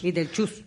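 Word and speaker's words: Bitelchús: En español se tradujo como Beetlejuice. – Beetlejuice. – Con Che y acento en la U.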